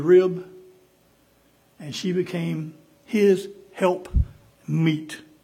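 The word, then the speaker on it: helpmeet: Not help mate, M-A-T, but help meet. Okay? She was meat for him. And not meat like meat to eat, but meat. Amen? My pronunciation sometimes makes it think like I'm talking about pork chops or something. But a help meet for him.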